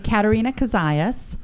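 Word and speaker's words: Katarina Kozias: It's just my name, Katarina Kazayas.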